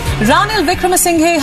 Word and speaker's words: Ranil Wickremesinghe: Ranil Wickremesinghe has now been appointed as the acting president of Sri Lanka. – That's how you say it, apparently. You don't spell it out like I did.